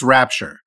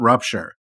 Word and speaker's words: rapture: No one actually believes in the rupture. But when the evangelicals come, everyone kisses them. I have to clarify this from pronunciation perspective because I'm enjoying it, but it's rapture. It's not rupture.